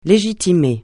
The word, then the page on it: légitimer: francais